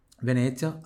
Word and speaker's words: Venezia: i famosi canali di Venezia. Pronuncia pure tu di Venezia, aqui tem som de TS igual pizza.